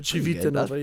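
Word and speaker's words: Civitanova: This Tuesday, I'll be facing Lube Chitinova. Did I pronounce that? Was that pretty good? – Chitinova, yeah. – Decent. Chitinova. Better than usual.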